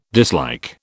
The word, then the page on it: dislike: British English